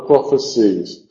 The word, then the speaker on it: prophecies: For all the prizes are the prophecies. Prophecies, hay que falsificarlo un poquito, poniendo un poco de énfasis en la última sílaba, que no es muy natural en inglés moderno, pero tampoco suena fatal.